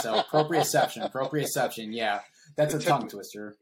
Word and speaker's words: proprioception: And so there's, I always forget how to say this correctly. So you might have to remind me, proprioception, the proprioception. Yeah. You said it right.